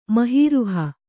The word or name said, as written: ಮಹೀರುಹ